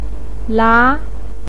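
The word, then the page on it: la2: pinyin; tone 2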